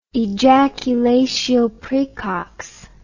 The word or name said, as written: ejaculatio praecox